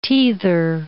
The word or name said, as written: teether